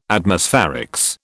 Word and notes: Atmospherics: British English

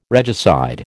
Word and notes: regicide: American English

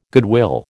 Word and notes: goodwill: American English

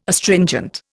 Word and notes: Astringent: Indian

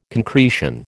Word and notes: concretion: American English

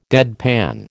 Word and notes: deadpan: American English

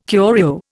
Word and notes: curio: Indian

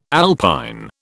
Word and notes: alpine: British English

